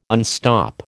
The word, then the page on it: unstop: American English